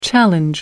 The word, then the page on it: challenge: American English